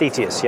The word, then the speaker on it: Thetius: Theatus, yeah. – Theatus. So where did that company name come from? – So Thetis, or I think I'm butchering it, I think it's Thetis, is the, a Greek goddess who had the gift of foresight.